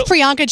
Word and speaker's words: Priyanka: So I'm her name's Priyanka Chopra, by the way.